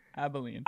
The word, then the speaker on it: Abilene: I think we have hit Abilene. Oh, how do you say that? There's no L before the B. – Abilene. – Abilene. – There you go.